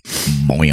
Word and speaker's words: Boyne: I used to work up near Boyne City, boing, boing, boing, boing. and that's, That's how uh, the that's how the natives pronounce it.